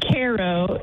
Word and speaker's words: Cairo: It's pronounced Cairo in this parts of the world.